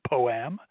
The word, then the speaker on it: POAM: It's an actual acronym in DoD, and it's even more important that you actually pronounce it as a word, POAM.